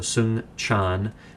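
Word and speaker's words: Soong-Chan: It's a Korean name. It's spelled S-O-O-N-G hyphen C-H-A-N. So Sung Chan, and then the word Ra, R-A-H, is his last name.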